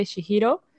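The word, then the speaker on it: Chihiro: Y vi otra que se llama El viaje de Shihiro.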